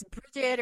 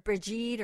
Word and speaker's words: Brigid: It's Bridget or Brigid or Bride. It's said many different ways, but Bridget most commonly, I think.